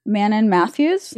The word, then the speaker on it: Manon Mathews: Manon Mathews? – Yeah.